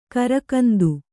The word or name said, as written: ಗೀರುಗಂಧ